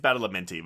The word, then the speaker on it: Badalamenti: Angelo Badalamonte just goes, blah. Did I get the name right? – Yeah, I think it's Badalamenti, but it doesn't really matter. You were really close.